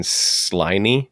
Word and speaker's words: Sliney: This is by Saxon Sliney. I don't know how to pronounce the last name. It's Slimy, but with an N.